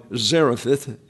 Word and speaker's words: Zarephath: So with the forecasted drought now underway, the Lord commanded Elijah to leave the brook, travel northwest to the coastal town of Zarephath, is the way it's best pronounced, where the widow will provide him his logistics, and that's verses 3 through 11.